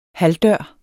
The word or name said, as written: halvdør